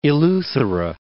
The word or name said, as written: Eleuthera